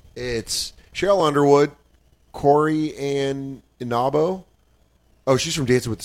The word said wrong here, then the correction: Carrie Ann Inaba: It's pronounced Carrie Ann Inaba.